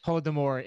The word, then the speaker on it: Holodomor: the Holodomor. – Holodomor in Ukraine.